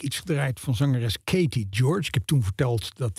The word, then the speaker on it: Gyorgy: Het is G-Y-O-R-G-Y. -G y wat natuurlijk een eh, Hongaarse achtergrond suggereert. En daarmee ook meteen eh, eh, de, het besef dat die laatste Y natuurlijk nooit wordt uitgesproken. dus het is inderdaad Katie George moet het worden uitgesproken.